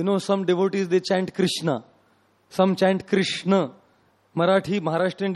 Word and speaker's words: Krishna: And then you will find Bengali devotees chant Kishno. All kinds of the pronunciations will hear, you know, Krishna, Krishna, Kishno, Kishno, some chant Hare Kisan.